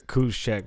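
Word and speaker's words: Juszczyk: Kuzcheck, whatever. The J is silent.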